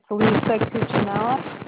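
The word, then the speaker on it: Lisa Cucinella: It's Lisa Cuccinella. – L-I-S-A.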